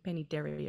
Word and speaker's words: Penny Terry: I'm pretty easy to find, Penny Terry. It sounds like a name out of a kid's cartoon. Penny Terry, it rhymes.